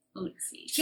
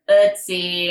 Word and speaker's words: Ötzi: Repeat after me. Ötzi. – Ötzi. – Can you just say his name, like, Ötzi. Right once? – Ötzi.